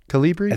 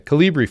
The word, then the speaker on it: Calibri: Am I saying that right? Calibri? – I think so. That's how I say it. – Okay, Calibri font.